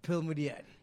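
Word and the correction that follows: Pearl Modiadie: Yes. Pearl Mudiadie. You must say it right.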